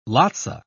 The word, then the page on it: lotsa: British English